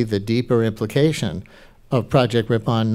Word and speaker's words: Ripon: Yeah, it was called, I called it Ripon, but I got corrected a while ago by a member of Congress that I should pronounce it Ripon, because... – It's R-I-P-O-N.